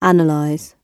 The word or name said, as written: analyse